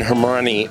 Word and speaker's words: Hermani: We're talking with MLive's Jordyn Hermani. Rhymes with Germany. We got to get Hermani in there.